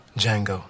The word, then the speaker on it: Django: Django. – The D is silent.